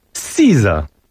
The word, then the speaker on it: SZA: SZA.